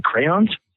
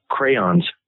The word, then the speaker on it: crayon: Okay, this is an orange crayon. Crayon is how you say it.